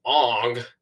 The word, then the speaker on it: Aang: Don't rant at us for messing up his name, because this is how the movie pronounces it. A 12-year-old boy named Aang and his flying pet bison named Appa.